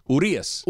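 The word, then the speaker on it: Urías: The accent is over the middle syllable. – Say it again. – Urias. Yes. The high part's in the middle.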